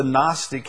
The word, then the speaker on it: Gnostic: The Gnostic, the word is spelled G-N-O-S-T-I-C, Gnostic.